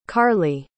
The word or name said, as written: karli